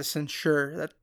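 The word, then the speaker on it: censer: Oh, it could be a censor, yeah. – It's a censure. That's the pronunciation.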